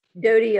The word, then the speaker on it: Dodie: Is it Dodie or Dottie? – Dodie Osteen. Yeah. – Dodie. Okay.